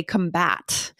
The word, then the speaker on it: combat: We actually change the pronunciation is the first thing we need to know. We say combat, right? Which is to prevent or to stop something. So take note, guys, the pronunciation, the emphasized syllable does change between the noun and the verb.